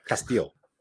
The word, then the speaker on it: Castillo: And then there's one more thing to explain, and that is that there's a 10th region, essentially, that's called the Castillo. And when you're playing the game on the table, and you must pronounce it like that, by the way, Castillo.